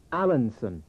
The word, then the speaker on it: Allenson: It's Allenson.